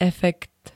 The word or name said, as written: efekt